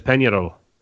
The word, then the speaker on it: Peñarol: So not even pronounced it correctly, too. You can look at Peñarol. – Yeah, you did. – Yeah. You can Americanize it and say Peñarol, but anyway.